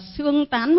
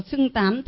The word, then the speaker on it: xưng tán: Thế thì chúng con là người mới, mà một bên thì đọc là xương tán, một bên đọc là xưng tán. Thế thì bây giờ nó là chữ xưng hay chữ xương ạ? – Xưng tán là phát âm đúng.